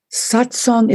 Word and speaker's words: satsang: Satsang is a Sanskrit word. You could spell it S-A-T-S-A-N-G, like sat sang, satsang.